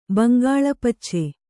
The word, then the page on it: ಬಂಗಾಳಪಚ್ಚೆ: bangāła pacce